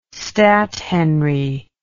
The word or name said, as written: stathenry